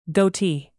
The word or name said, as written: goatee